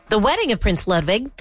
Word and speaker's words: Ludwig: Ludwig. – Yeah, well, in German, a W is pronounced as a V.